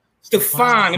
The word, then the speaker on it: Stephon: Stephon. – Let me say it right. Stephon. Not Stephon. Stephon. I make sure I say it right. Like Urkel's nemesis, right? – Yeah, like Urkel's nemesis, like Stephon Marbury.